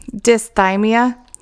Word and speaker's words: dysthymia: I said it right the first time, dysthymia remains unknown,